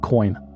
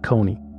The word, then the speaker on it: Kony: Ugandans pronounce it Joseph Coin, not Kony.